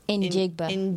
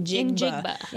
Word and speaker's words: Njigba: cannot pronounce his name, Smith Njigba. – Njigba.